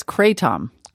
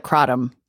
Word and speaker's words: Kratom: Many pronounce it as Kratom. – Kratom. – And others call it Kratom.